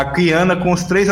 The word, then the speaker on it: Qiyana: então, eu vou deixar aqui, eu vou falar aqui, né, que Kiana, a pronúncia corretas, né, é Kiana, a terra de onde ela veio é Istal, né, I-X-T-A-L, Istal, e a cidade dela é Ishaokan, que até o pessoal ficou brincando, ah, Ishaokan do Mortal Kombat.